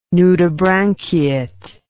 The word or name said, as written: nudibranchiate